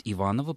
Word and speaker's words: Иванова: А по поводу башни Иванова, она, конечно, должна быть Иванова, потому что дворянская фамилия Иванов произносилась с ударением на... Да, второй слог.